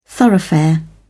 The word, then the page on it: thoroughfare: British English